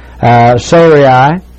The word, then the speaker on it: Sarai: it is Sarai, and I imagine the R-A-I should be less than two syllables, and I almost pronounce it as two syllables, but we'll call it Sarai